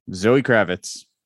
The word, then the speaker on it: Zoë Kravitz: According to Pronounce TV on YouTube, Zoë Kravitz. Pronounced exactly like you would expect. The dots do nothing.